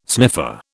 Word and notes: sniffer: British English

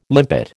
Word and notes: limpet: American English

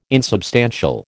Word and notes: insubstantial: American English

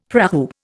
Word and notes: prahu: Indian

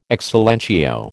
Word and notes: ex silentio: American English

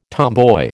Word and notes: tomboy: American English

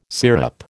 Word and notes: syrup: American English

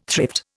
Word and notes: thrift: Indian

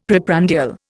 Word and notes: preprandial: Indian

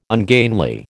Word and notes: ungainly: American English

